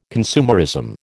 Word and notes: consumerism: American English

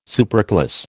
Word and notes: superclass: American English

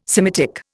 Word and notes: Semitic: Indian

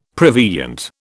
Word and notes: prevenient: British English

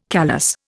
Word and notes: callus: Indian

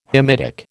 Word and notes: Hamitic: American English

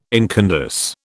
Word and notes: incandesce: British English

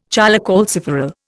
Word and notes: cholecalciferol: Indian

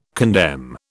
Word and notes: condemn: British English

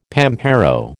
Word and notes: pampero: American English

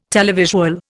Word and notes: televisual: Indian

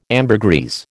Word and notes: ambergris: American English

